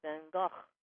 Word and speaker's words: van Gogh: Because he knew they would say Vincent van Gogh, and it's pronounced Vincent van Gogh, and nobody would ever say that.